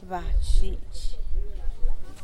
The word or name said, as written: badxxhidx